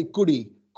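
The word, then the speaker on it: guri (ਕੁੜੀ): Like, you know how, like, obviously in Punjabi, they say, let's say, guri. Guri, even though you say it like with an R, K-U-R-I. – Yeah, yeah. – Guri. Guri is spelled K-U-D-I. But you can kind of see the closeness between the D and the R rolling into each other.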